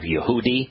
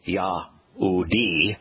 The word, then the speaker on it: Yahudi: rabbinic corruptions change the pronunciation of the hey in Hebrew so that it would be an eh to change the pronunciation of any word that contains Yahweh's name. It is Yahudi, and Yahudim would be Jews.